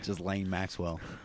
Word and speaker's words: Ghislaine: Ghislaine Maxwell. I think that's actually how you pronounce it.